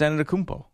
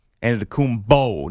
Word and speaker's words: Antetokounmpo: Giannis Antetokounmpo, mm-hmm. – and then you say it like that. Even though there's now a dispute between Mikey B and I about the end of that, he says Antetokounmpo.